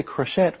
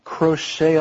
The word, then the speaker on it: crochet: You see that the T is not pronounced. You don't say crochet, we say crochet.